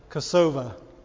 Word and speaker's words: Kosovo: We have friends that are missionaries in Kosovo, so they pronounce it Kosova.